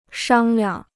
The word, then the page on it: 商量: shāng liang